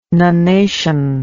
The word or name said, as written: nunation